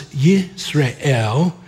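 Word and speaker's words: Yisrael (ישראל): There on your outline, El is always God, but the way it's pronounced is Yisrael.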